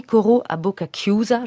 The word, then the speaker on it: Coro a bocca chiusa: Là, tout de suite, Paul Wittgenstein qui a fait un arrangement pour piano de cet air, extrait de Madame Butterfly de Puccini, Coro a Bocca Chiusa. Je ne sais pas si je le prononce bien. – Absolument.